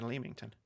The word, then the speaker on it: Leamington: Leamington. I don't know why I said Leamington. Pronounced Leamington. I used to live right next to it.